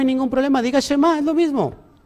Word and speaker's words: Shema: Que el otro día me dijeron que no es, no es Esma, que es Shema.